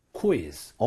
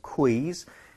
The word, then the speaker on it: quiz: 퀴즈. – 오, 퀴즈. – 나이스. 몸을 묶어서는 안 되죠? 퀴 이러면 안 돼요. 쿠이를 빠르게 하는 것뿐입니다. So quiz, quiz 이런 식으로 발음해 주시고요.